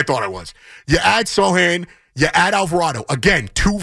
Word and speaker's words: Sochan: And you add Sochan, did I say that right? No? You can tell me what it was. – Sochan? – Yeah, you were close.